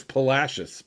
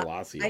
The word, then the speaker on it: Palacios: And it's Palacios, by the way, not Palacios.